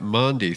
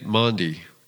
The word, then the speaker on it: Maundy: Not Monday Thursday, but Maundy Thursday. It is the Thursday of the mandate. Think Maundy, kind of rhymes with laundry.